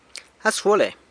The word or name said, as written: As guale